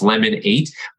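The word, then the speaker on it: Lemon8: So somebody in the Q&A just actually mentioned lemon, I think it's pronounced lemonade, like lemonade, but the mm-hmm. number eight. So it's lemon eight, which I've heard of.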